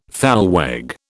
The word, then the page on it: thalweg: British English